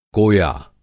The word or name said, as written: कोया